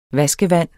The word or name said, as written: vaskevand